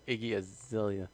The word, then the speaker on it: Iggy Azalea: No, not Ziggy Azalea. Or Iggy Azalea.